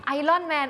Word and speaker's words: Iron Man: Iron Man ออกเสียงว่า Iron Man Iron มันจะเป็น Iron มันจะไม่มีตัว R อยู่ในนั้นแต่ว่ามันจะมีนิดนึงนิดนึง Iron Man Iron Man มันไม่ Iron Iron